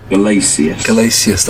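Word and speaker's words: Gelasius: So you're saying Galaeus, how do you say it? – Galaeus, that's it.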